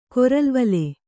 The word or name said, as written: ಕೊರಲ್ವಲೆ